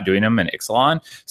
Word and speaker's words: Ixalan: Everyone started saying Ixalan. Then we're like, oh, the X in the Mayan dialect is pronounced the CH, which would be Ixalan. But Melissa DeTora confirmed that it is Ixalan with the X.